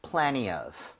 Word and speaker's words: plenty of: 때에 따라서 여러분들 plenty 대신에 t를 약화시켜서 발음 안 하는 경우도 많아요. So plenty of. – Plenty of. – 그런 식으로 가도 괜찮습니다.